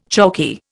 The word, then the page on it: chalky: Indian